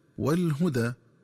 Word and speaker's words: الهدى: قراءتها بضم الهاء وفتح الدال ثم ألف مقصورة هكذا والهدى.